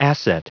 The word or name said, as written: asset